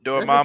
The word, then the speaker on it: Dormammu: Dormammu? – Skin Diamond. – Dormammu.